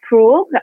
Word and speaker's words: Forough: So my name is Forough, and you pronounce it very well, Nikki.